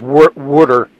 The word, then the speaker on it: water: Well, first of all, the proper pronunciation is water, W-O-O-D-E-R, water ice.